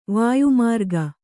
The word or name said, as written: ವಾಯುಮಾರ್ಗ